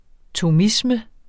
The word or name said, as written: thomisme